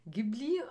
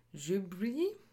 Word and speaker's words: Ghibli: Auch das passt dann wieder zu ähm, dem Vater, denn der Name an sich, also Ghibli ausgesprochen, wäre dann eher ähm, Ghibli.